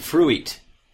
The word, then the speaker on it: fruit: Because it's spelled like F-R-U-I-T, so it's, you would think you'd pronounce it ooit. – Completely right? – Logical, ooit. Completely logical.